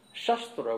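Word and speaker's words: Shastra (शस्त्र): Shastra means scripture, wisdom text, with a long A, Shastra. Shastra, with a short A, means weapons.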